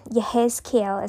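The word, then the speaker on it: Yehezkiel (יחזקאל): Yehezkiel is how you pronounce Ezekiel in Hebrew, or it's as close to it for me at this level in terms of someone who speaks English and British English at that.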